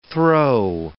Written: throe

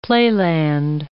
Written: playland

